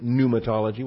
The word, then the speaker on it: pneumatology: So just pronounce it pneumatology with a silent P.